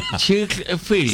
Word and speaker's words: Chick-fil-A: El Chick-fil-A, yo no sé la pronunciación, sea como la pronunció usted, porque usted es más gringo que yo. – Chick-fil-A. – Sí, así se escribe. – En Boston, Estados Unidos. – Sí, como de chicken, como de pollo, sí.